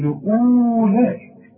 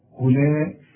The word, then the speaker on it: أولئك: شوف أولئك اوعى تقول أولئك لا أولئك كأن الواو غير موجود.